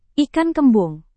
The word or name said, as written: ikan kembung